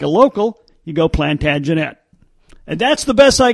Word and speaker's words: Plantagenet: If you listen to historians talking about that dynasty, they pronounce it Plantagenet. Plantagenet. So, if you want to sound like a historian, you go with Plantagenet.